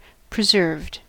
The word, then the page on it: preserved: American English